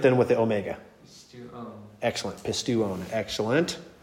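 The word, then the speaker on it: pistuon (πιστεύων): So it's pistuon, pistuon. Because the epsilon and the upsilon form a diphthong. Mm-hmm. But then the omega has to be pronounced by itself because that's not a diphthong. So you have two vowel sounds there.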